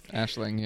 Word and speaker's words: Aisling: Aisling. – Aisling, yeah.